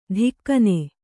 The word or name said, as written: ದಿಕ್ಕನೆ